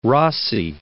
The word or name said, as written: Rossi